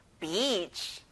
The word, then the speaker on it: beach: 친구들, 이거는 긴 단어예요. 꼭 길게 발음하셔야 돼요. beach.